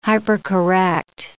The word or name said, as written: hypercorrect